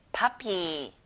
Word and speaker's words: puppy: 강아지, puppy. 퍼피가 아니라 아 소리가 나요. puppy.